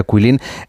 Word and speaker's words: Guilin: Bueno, esta ciudad de Kuilin, por si alguien la busca en internet, se escribe Guilin, como si fuera Guilin, pero se pronuncia Quilín.